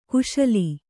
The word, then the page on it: ಕುಶೀಲೆ: Kannada